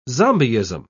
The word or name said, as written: zombiism